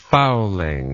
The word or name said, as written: fouling